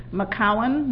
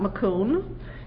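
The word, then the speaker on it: Macoun: McCowan, not McCoon, is... you knew you were saying it wrong.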